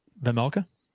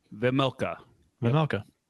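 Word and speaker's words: Vejmelka: How do you say his name, Kurt? – Vemelka. Vemelka. Yeah. I was right.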